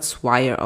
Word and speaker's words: Swire: Ich glaube, das spricht man Swire aus, also S-W-I-R-E.